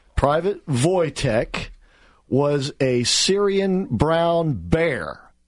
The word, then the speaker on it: Wojtek: Well, Wojtek. Wojtek is his name. You spell that W-O-J-T-E-K.